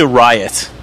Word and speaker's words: riot: He pronounced pussy riot and he rolled the R in riot, and she goes, no, don't roll the R. You pronounce like the Americans pronounce it. Pronounce it riot.